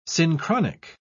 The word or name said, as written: synchronic